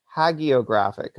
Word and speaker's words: hagiographic: but it has a hard G. It's hagiographic, apparently.